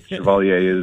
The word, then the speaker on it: Chevalier: I mean, that's the French pronunciation, I would think. How do you say your last name? – Canadians might know that. That is the correct pronunciation. Chevalier is right.